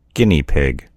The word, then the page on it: guinea pig: American English